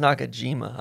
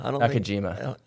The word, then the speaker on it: Nakajima: I think it's Nakajima. – Nakajima. – Sorry. I don't think the J is silent like an H.